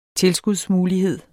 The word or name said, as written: tilskudsmulighed